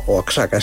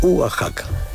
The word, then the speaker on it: Oaxaca: Atención a esta palabra, Oaxaca, que la escribimos Oaxaca, y mucha gente la pronuncia como Oaxaca, es una ciudad del centro de México. Se pronuncia Oaxaca.